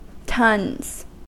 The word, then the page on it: tons: American English